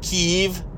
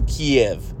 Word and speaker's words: Kyiv: Now, I don't know why everybody's pronouncing it Kiev, which it is sort of sounds like Kiev, but it's still Kyiv. There's a Y and an I.